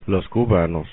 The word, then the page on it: cubanos: Spanish